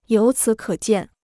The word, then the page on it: 由此可见: yóu cǐ kě jiàn